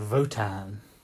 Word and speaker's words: Wotan: And it was, and it's got a big computer in it called Votan. Even though it's spelled Wotan. Apparently we've got to say Votan. – So it's spelled Wotan. – It's like kind of German, I guess. Votan would be like, if you said it, if you Germans pronounce their W's as V's, Votan.